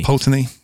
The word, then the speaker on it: Pulteney: Old Pulteney. – Pulteney.